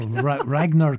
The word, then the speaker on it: Ragnar: There's a double pass to the National Gallery of Victoria's Ranier Kjartansson. – Pronunciation's right next to the word. – Oh, Ragnar Kjartansson.